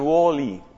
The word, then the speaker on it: WALL-E: W-A-L-L, guión y la letra E. Entonces eso en inglés lo pronuncian WALL-E.